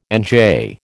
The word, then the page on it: NJ: American English